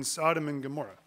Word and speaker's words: Sodom and Gomorrah: He ended up in Sodom and Gomorrah. Is that right? Did I say that right? Yes? Yeah, okay, I'm just thinking there.